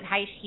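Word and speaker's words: Hsieh: You know, what Tony Heiss did with, you pronounce it Heiss,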